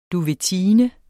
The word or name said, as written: duvetine